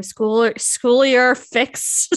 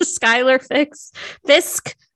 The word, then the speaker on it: Schuyler Fisk: Schuyler Fisk, Schuyler Fisk.